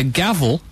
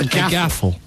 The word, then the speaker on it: gavel: From people saying it's either a gavel or a gabble. – Ah. – Not, as you incorrectly pronounced, a gaffle.